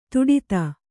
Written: ಧಡೂತಿ

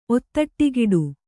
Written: ಒತ್ತಟ್ಟಿಗಿಡು

